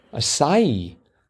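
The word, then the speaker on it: açaí: Acai. It's a soft C.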